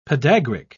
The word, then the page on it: podagric: British English